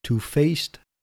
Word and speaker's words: two-faced: Two-faced or two-faced towards me. Let me check that. All right, I've checked it. And I should stress the second part.